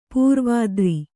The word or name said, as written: ಪೂರ್ವೋತ್ತರ